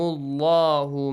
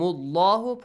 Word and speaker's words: الله: فريحين بما آتاهم الله من فضله. آتاهم الله. – Pronounce Allah with a heavy sound.